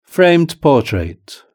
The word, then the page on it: framed portrait: British English